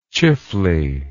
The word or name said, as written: Chifley